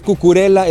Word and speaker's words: Cucurella: Sí, no es Cucurella, es Cucurella, él mismo lo pronuncia de esta forma, y dice Cucurella que él prefiere jugar feo, que no le importa ser un fútbol horrible, pero seguir avanzando.